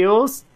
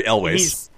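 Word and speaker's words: Elwes: Ewells? Is it? – Kerry Elways. – Elways, is that how you say it? – Just think John Elway, but Elways.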